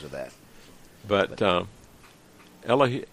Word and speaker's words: Elihu: Um, before I learned, I learned in recently in Israel, talking to a couple of people, that in Hebrew they usually emphasize the last syllable on some of these words. – Oh, really? Elihu.